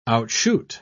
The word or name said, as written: outshoot